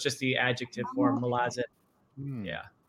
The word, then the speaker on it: Malazan: Or may I ask, am I pronouncing that correctly? – Malazan? It's fine, however you say it, but I believe the way Erickson would say is Malazan.